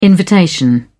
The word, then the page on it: invitation: British English